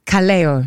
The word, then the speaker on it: Kaleo: He said to me, Kaleo. – Kaleo. – Kaleo. – Like Bamboleo. – Yes, but Kaleo.